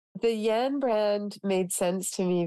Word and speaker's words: Yanne: Y-A-N-N-E. – And it is pronounced? – Yan.